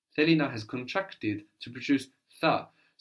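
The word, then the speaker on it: tha (θα): Now tha is spelled with that letter that looks like a zero with a horizontal line running through the middle, which gives us th, tha.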